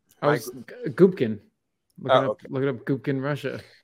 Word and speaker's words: Gubkin: It's Gubkin.